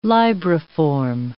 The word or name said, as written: libriform